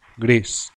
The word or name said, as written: gris